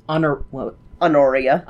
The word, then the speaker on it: Honoria: Honoria. – Honoria.